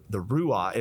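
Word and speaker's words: ruach: It's the breath, the ruah is the, I definitely did not pronounce that correctly. You got to have more of like a huh in there somewhere.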